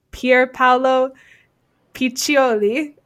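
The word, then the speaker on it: Pierpaolo Piccioli: but we consulted an Italian in the pronunciation. We believe it to be Pier Paolo Piccioli of Valentino, who won Women's Designer of the Year, and I just, oh, wow.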